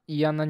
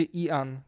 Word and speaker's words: Ian: I to jest takie dziwne, bo jego pisze się Ian, czyli normalnie powinno się mówić Ian. A wszyscy mówią Ian, Ian i jest w końcu geneza tego imienia.